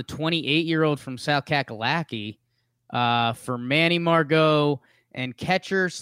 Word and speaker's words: Margot: The Rays traded Emilio Pagan to the Padres for center fielder Margot Murgat. Is that how you say his name? – Manny Margot.